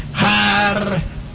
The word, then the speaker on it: Harpócrates: Los místicos de los misterios de Eleusis pronunciaban aquel nombre en la siguiente forma: Harpócrates.